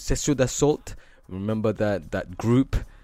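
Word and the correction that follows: Sexion d'Assaut: Let me make sure I'm saying it right, actually, because how embarrassing it would be with my French teacher would be very, very but Section d'Assault, if you remember that hip-hop group from France.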